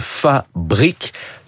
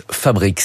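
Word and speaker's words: Fabrick: Clarisse Merlet, fondatrice de Fabric. Ça s'écrit Brick, B-R-I-C-K, brique donc à l'anglo-saxonne. Et je rappelle donc, vous êtes architecte et c'est le fruit de vos années d'études, ce projet, cette entreprise Fabric.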